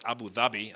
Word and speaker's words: Abu Dhabi: Getting right into it, Abu Dhabi is, it's actually pronounced Abu Dhabi, Abu from father, Dhabi is a type of deer or gazelle.